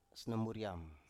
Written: snomuriam

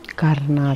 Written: karnad

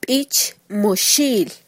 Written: bich

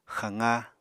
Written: xaqá

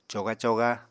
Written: coga-coga

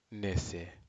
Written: nese

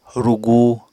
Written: rugu

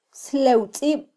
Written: slewtzib

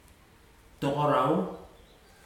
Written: tokorau

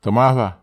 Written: temahwa

